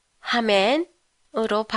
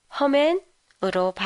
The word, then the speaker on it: 하멘: 드라마에는 허멘이 아니라 하멘으로 발음하고 있는데요. 사람에 따라서는 허멘으로 발음할 때도 있어요.